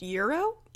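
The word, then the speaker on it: Iero: We haven't learned this yet, but he is modeled after Frank, I think it's pronounced Euro, who was, I think, the lead guitarist in My Chemical Romance.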